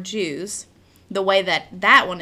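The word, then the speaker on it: Juwes: The Jews. That's how it's pronounced, but like I said, it's J-U-W-E-S.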